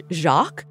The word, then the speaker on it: Jacques: And they pronounced it like the French Jacques.